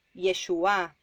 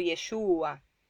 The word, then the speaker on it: Yeshua: Es Yeshua, con acento en la A, no como Yeshua.